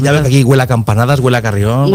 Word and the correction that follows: carillón: Cari, carillón. – Carillón. – Carillón. – ¿Sabes qué digo carrillón? – Claro, yo he dicho carrión.